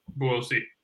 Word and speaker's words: Buosi: Now, forgive me if I say it wrong. Buosi? – Buosi. – Buosi. Okay, my apologies.